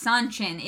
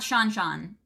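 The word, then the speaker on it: Seanchan: No, it's Sanchin. It says it in the book. – People keep saying it's Shanchan. – No. It's not Shanchan.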